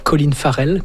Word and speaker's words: Colin Farrell: Et puis, donc, Sugar, ça, c'est avec Colin Farrell. Je ne sais pas si je le prononce correctement. – Oui, oui. – Colin Farrell.